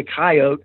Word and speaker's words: coyote: So they simplified it to just two syllables, to coyote. They took it back to the South and to the Midwest. And in much of the country, that pronunciation has prevailed right down to the present day.